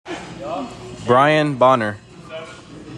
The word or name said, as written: BRIAN BONNER JR